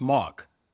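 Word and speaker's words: Mauch: It's Mock. – Mock. All right. Okay. – It's pronounced M-O-U-C-K, Mock.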